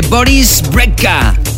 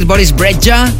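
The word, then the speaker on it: Boris Brejcha: Estamos a punto de terminar esta primera hora de programa, ahora escuchando la última de Boris Brecka. Así dicen que se pronuncia, aunque también podemos decir Boris Breja.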